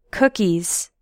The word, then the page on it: cookies: American English